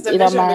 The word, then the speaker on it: DVSN: Is that how it's pronounced? Is that the DVSN? – Yeah. – I didn't know that's what they're called. I just always thought it was DV. I think it's Division because one of their old albums had the Division sign on it.